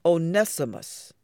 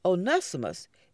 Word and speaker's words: Onesimus: Now Cotton Mather gave this enslaved person the name Onesimus. And Onesimus is spelled O-N-E-S-I-M-U-S.